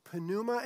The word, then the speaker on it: pneuma: You pronounce the P. It's pneuma in Greek and ruach in Hebrew.